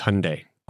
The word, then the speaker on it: Hyundai: It's Hyundai. – Hyundai. – Rhymes with Sunday.